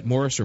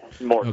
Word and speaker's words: Morse: Now, Nick, John, Senator, his name, Morse, right? Morris or Morse? – Yeah, Morse.